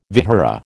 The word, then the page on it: vihara: American English